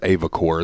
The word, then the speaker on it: Avocor: Yeah, it's Avocor. I watched the video they said. I think it's Avocor is how they pronounce it.